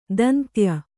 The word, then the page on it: ದಂತ್ಯ: dantya